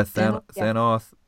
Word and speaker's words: Thanoth: Yeah, and Inspector Thanoth, is that how you pronounce it? – Yeah, Thanoth.